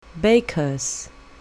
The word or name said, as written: baker's